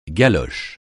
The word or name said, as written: galoche